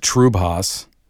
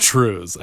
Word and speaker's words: triubhas: And then another word, another spelling, T-R-I-U-B-H-A-S. – Oh. – I saw the pronunciation. I looked it up. There was a video that pronounced it Trubhas, but there is what I got into, a Scottish dance called the Shan Trues. Word spelled T-R-I-U-B-H-A-S, pronounced trues. – Jeez Louise. – So it is pronounced trues. I looked it up and made sure.